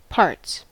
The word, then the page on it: parts: American English